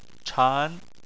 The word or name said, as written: chan2